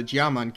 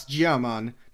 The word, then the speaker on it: Giaman: Giaman kingdom to their north. And yes, that's Giaman, not Giaman, like I kept saying last time.